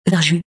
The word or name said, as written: Verjus